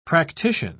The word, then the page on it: practician: British English